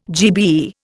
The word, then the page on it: GBE: Indian